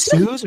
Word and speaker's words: Suze: So it's Suze or Susie? – It's actually Suze. That's how she pronounces it. She pronounces it Suze in the Suze. But I was like, I've always said Susie, but you know, Suze Orman.